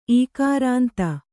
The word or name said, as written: ಇಕಾರಾಂತ